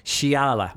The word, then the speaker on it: Xiala: And that is Xiala, which is spelled X-I-A-L-A. But because you're using some X's in there, a bit like the Mexican usage, I guess. So this is Xiala.